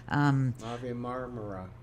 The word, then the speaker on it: Mavi Marmara: The Human Rights Council denied their report that is challenging Israel's previous murder of 9 people on the Turkish ship Mavi Marmara. How would you pronounce it? – Mavi Marmara.